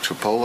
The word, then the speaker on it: Chipotle: What's that place, I don't want to mispronounce it, Chipotle? Right? Because I think I said Chip something that was wrong.